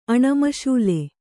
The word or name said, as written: ಅಣಮಶೂಲೆ